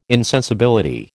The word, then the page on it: insensibility: American English